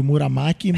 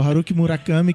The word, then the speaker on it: Haruki Murakami: Ó, a pronúncia do Dolingo aí que é do Haruki Murakami, que eu já chamei de Muramaki, mas acho que Muramaki é um tipo de sushi. é o Haruki Murakami, que é um é o, o escritor da moda do Japão aí.